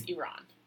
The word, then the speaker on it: Iran: So they're scattered kind of in like the Iran area, and then mostly they're in sub-Saharan Africa. – Iran. – Whatever. not whatever, that's like a country, I should pronounce it correctly, I'm sorry, it's Iran.